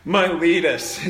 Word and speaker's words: Miletus: And in verse 17, Paul is at Miletus, or Miletus. I don't know how you pronounce it. Miletus is the English pronunciation.